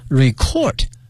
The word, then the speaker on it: record: R-E-C-O-R-D, 这个发音同学们一定要小心了， record 是动词表示录音哈，录制的意思，作为名词呢它的发音呢是 record, 可以指呢我们说记录或者说唱片等等。